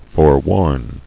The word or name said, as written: Forewarning